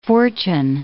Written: fortune

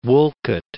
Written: Woollcott

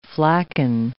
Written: flacon

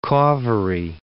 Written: Cauvery

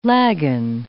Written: lagan

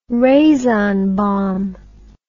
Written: razon bomb

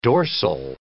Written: dorsal

